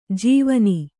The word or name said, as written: ಜವಾನಿ